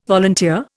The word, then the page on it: volunteer: Indian